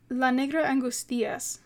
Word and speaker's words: La Negra Angustias: And there's this film, it's called, I think it's La Negra Anguituza? Hold on, let me double check, because I feel like I butchered that pronunciation. It is called, referring to my notes, La Negra Angustias.